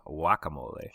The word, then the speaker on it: guacamole: Wikipedia informs me that if I'm being accurate, I shouldn't pronounce the G. I should be saying guacamole.